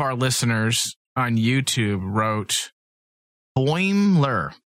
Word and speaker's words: Boimler: No, Boimler. There's not three syllables. Two.